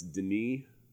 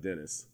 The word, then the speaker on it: Denis: but it's Denis, not Dennis.